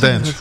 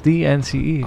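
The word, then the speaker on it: DNCE: Zeg ik dat goed? Of is het gewoon dance? – Nee, dat is D&CE.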